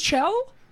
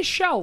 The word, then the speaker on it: Chell: It's Chell? I say Shell, like Michelle. – But it's spelled C-H-E-L-L. – Yeah, like Michelle is.